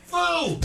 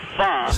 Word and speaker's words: pho: So the correct pronunciation is... – Foo! – Foo! – Foo! – No pho. – See? Yeah. If you start off with pho, you are... – That's an O. That's a long O.